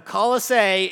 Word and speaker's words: Colossae: If you want to know, Colossae is how you correctly pronounce this little town that Paul was writing to.